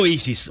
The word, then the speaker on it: Oasis: Se pronuncia Oasis, ¿ok? – Oy.